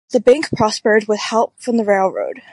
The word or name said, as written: prospered